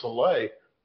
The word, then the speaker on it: Saleh: You know what was odd about the way that was handled was after the game, Jets coach Robert Saleh, I guess that's how you pronounce it.